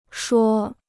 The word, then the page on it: 说: shuì